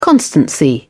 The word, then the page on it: constancy: British English